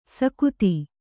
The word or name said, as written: ಸಕುತಿ